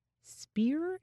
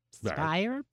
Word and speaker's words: Spier: There was a woman named Hortense Speer, if you want to pronounce it S-P-E-E-R, but it's spelled S-P-I-E-R. Spire, perhaps.